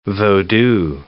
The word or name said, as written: vodou